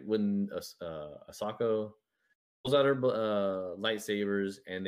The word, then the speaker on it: Ahsoka: Ahsoka. Ahsoka.